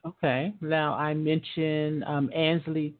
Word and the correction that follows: Ainsley: it, am I pronouncing that right, Ainsley? – Yeah, Ainsley Taylor.